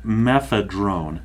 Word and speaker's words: mephedrone: There's another one called methedrone, M-E-P-H-E-D-R-O-N-E, and then another one called methylone, M-E-T-H-Y-L-O-N-E. – Methadrone. Mm-hmm. Methadrone is methadrone. The one that I have mispronounced before. I think I said it was something methaphone, but that's the one I was referring to. So methadrone.